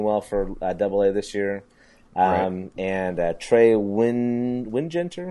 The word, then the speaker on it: Wingenter: They pronounced it like there's two G's there, so it's like Wingetter.